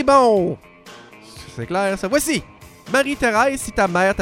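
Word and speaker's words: Marie-Tharèse: Et là, je le prononce un peu croche, me diras-tu? Non, c'est parce que ça s'écrit bel et bien comme ça dans le titre de la pièce. Thérèse, T-H-A-R-E, accent grave S-E. Marie-Thérèse.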